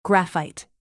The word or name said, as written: graphite